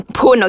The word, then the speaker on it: Phoon: If you are tuning in for the first time, hi, my name is Emma Poon. That's pronounced as P-H-O-O-N, Poon, okay? Not like phone or whatever, okay?